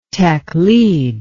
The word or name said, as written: taqlid